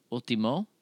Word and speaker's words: último: How do you say, last episode would be Ultima Episodio? – Último. – En la último episode. En el último episodio.